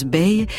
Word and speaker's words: BAE: Sachez donc pour commencer que B-A-E se prononce Bay et qu'il s'agit de l'acronyme des mots anglais Before Anyone Else qu'on peut traduire par avant quiconque ou plus que n'importe qui.